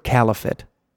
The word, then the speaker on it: caliphate: So there was a hacker group called the Lizard Squad that described itself as a cyber caliphate.